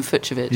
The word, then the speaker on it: Fucsovics: I went to lengths to ascertain how to correctly pronounce his name. So there you go, Martin Fucsovics.